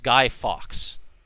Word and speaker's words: Guy Fawkes: It's Guy Fawkes. – Guy Fawkes. – Like if there were multiple hawks.